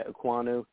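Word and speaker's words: Ekwonu: So you went with Ikia Ikemekwe. Ooh, geez. I always butcher his name. Poor guy. Aquanu, thank you.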